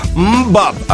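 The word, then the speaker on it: MMMBop: Es un título onomatopéyico y eso quiere decir que te voy a pronunciar un sonido gutural, ¿eh? Es que esto se llama Mbop, así como suena, ¿eh?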